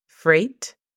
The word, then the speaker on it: freight: La pronunciación fonética sería r E-I-T, freight, train, T-R-E-I-N.